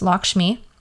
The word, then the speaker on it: Lakshmi: Yes, Lakshmi.